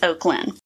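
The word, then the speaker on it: Oaklynn: So oak as in like the tree, L-Y-N-N. – Okay. – Together. – That's pretty. – So instead of oak and Lynn, it's Oaklyn. So it's one of those Lynn ending names, and it rose from 888 in 2017 to 542 in 2018.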